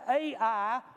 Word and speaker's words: Ai: they were ready to go up to Ai, or I, depending on who you are, how you want to pronounce that little city. Both enunciations are correct. Ai is the spelling. You can either call it Ai or I.